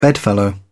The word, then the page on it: bedfellow: British English